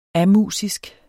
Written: amusisk

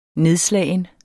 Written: nedslagen

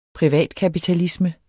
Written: privatkapitalisme

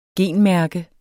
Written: genmærke

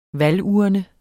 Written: VALGURNEN

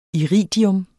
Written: iridium

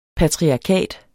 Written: patriarkat

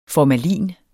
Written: formalin

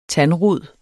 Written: tandrod

